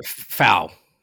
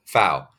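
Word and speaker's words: Pfau: Is it Fa or Fa? – Fau. – Fau.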